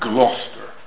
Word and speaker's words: Gloucestershire: They spell it Gloucestershire, but they pronounce it Gloucester.